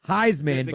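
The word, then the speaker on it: Highsman: Heisman, but High is spelled H-I-G-H. Heisman.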